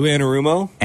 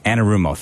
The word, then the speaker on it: Anarumo: Lou Anarumo? – Anarumo. Thank you. I had it. I was like stuck in the back of my head there. Lou Anarumo, he does always concern me a little bit.